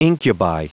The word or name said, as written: incubi